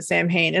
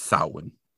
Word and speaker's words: Samhain: So I also blame the real Ghostbusters because I've also always called it Samhain. And I think I have heard before that that's not correct, but it's never really penetrated my brain. And so I think I have always just said it wrong. – Yeah, it's Samhain. Yep.